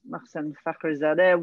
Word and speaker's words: Mohsen Fakhrizadeh: Where I first came across Mohsen Fakhrizadeh was when he was cited. Mark is shaking his head at me because I pronounced his name properly.